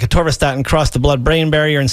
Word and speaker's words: atorvastatin: Recently suggested an alternative to atorvastatin. Did I pronounce that right? – Atorvastatin.